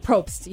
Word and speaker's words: Probst: Isn't it Jeff Probst? I don't know how to say his last name, but it's P-R-O-B something. – Yeah, close enough. Jeff Probst.